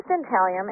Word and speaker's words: dentalium: But you have an antique box of Detalium Native American Vintage. Did I mispronounce that? What that is. – It is dentalium,